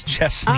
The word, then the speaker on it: Chesney: Everybody always does with a Z, and if you ever listen to him say his own name, it is Chesney.